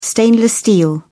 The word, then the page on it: stainless steel: British English